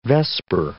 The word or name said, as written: vesper